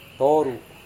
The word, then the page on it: toru: Serewen